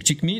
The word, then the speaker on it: XGIMI: Il y a également une marque dont on a beaucoup parlé, je vais la prononcer à la chinoise, c'est Chikmi. Donc Chikmi X-G-I-M-I,